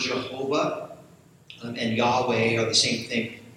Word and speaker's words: Yahweh: Jehovah is a mispronunciation. It's a misunderstanding of the Hebrew vowels that are missing, the consonants YHWH. And back in the old days, like the 14th, 1600s, they inserted the wrong vowels there, a misunderstanding.